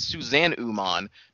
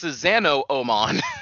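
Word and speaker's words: Susanoo: Uh, it's very stupid because it's spelled with like two O, so I thought it was like, oh, maybe it's Suzanne Uman. Nope, it's Susano Oman.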